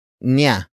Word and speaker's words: niya: The next word is niya, niya, niya. Niya is spelled as N-I-Y-A.